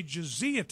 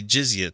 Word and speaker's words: jizya: You only have to pay jizya tax or jizya. He says jizya, but it's J-I-Z-Y-A. It's not jizya. There's no extra syllable in the middle there.